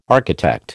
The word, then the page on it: architect: American English